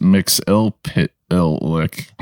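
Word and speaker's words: Mxyzptlk: His name is pronounced Mix-il-pit-il-lick. Mix-il-pit-il-lick.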